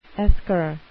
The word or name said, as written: esker